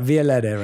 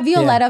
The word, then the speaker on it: Violetta: Violetta, right? – Yeah, Violetta, yeah.